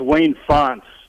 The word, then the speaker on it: Wayne Fontes: How do you say that name? – It's not Fountain DeWayne? – Yeah, it's Wayne Fontes there.